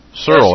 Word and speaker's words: Searle: But I guess the name of the company, I don't know if I'm going to pronounce this right or not, I think it's pronounced Searle. – Searle, that's right. – S-E-A-R-L. – Yeah. – Well, according to what I read about it... – I think there's an E on the end.